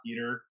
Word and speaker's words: Petr: and it's Petr Yan, Pietra? I don't know. It's like... – I think it's actually Yan. Just Peter.